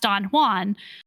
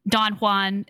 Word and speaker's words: Don Juan: That's actually the right way to say it if you if you are someone who's read Don well Don Juan, Don Juan, as a lot of people incorrectly say it by Lord Byron, because that poem is actually a satire, and so the whole point is is that like an English person would mispronounce that word that name.